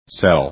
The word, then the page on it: cell: english